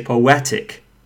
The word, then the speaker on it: poetic: So if you have the word like poetic, so po-a-tic, three syllables, and it ends with in ik, then you know that the second one from the right, a, the syllable a, must be stressed. So you have to say poetic.